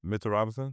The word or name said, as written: Mitchell Robinson